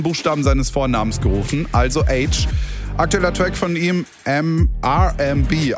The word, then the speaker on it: Aitch: Jetzt mit neuer Musik, die kommt vom britischen Rapper H, also ausgesprochen wie das englische H, also das von TH. Ihr checkt, ne? Aber nur ein H wäre als Künstlername vielleicht zu kurz gewesen, deswegen wird es A-I-T-C-H geschrieben. Ja,